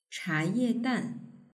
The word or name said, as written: chayedan